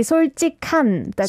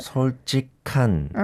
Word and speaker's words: 솔직한: It has to be 솔직한, the k sound. – 솔직한.